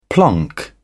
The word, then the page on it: plonk: British English